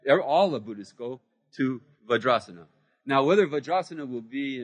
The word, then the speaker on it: Vajrasana: Vajrasana. Very good. See? You're learning. So that would become a long A. That would become a long A there. Vajrasana.